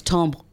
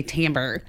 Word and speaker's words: timbre: Because the French one is timbre, you know, and then Americans say timbre.